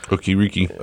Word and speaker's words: Okereke: Bobby Okereke, is that how we pronounce that? – Okereke.